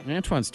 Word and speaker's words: Antoine: Antoine's too weird of a name for them.